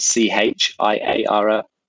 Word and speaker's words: Chiara: It's spelled C-H-I-A-R-A, okay. C-H-I-A-R-A over there. But we liked it with a K, so we changed it.